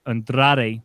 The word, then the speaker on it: Andrade: I can't even say it right. Andrade. There we go. – Hey, good. Roll that R.